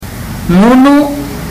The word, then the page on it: gunu: fiji